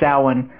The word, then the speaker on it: Samhain: And actually Samhain, which is spelled like it would, if you're speaking American, it would say Samhain. But it's Gaelic, so it's pronounced Samhain.